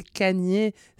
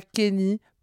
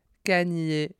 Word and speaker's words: Kanye: Eh bien, vous l'avez entendu dans ma bouche depuis le début de l'épisode, c'est Kanye, c'est pas Kenny, pas Kanye. Kanye.